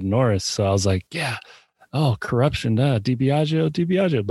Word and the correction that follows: DiBiagio: But it's of course DiBiago.